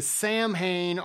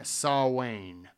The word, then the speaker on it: Samhain: Celebrants believe that the barriers between the physical world and the spirit world break down during Samhain, or Samhain. It's so weird because it's spelt Samhain. Anyways, so it allows for more interaction between humans and denizens of the other world.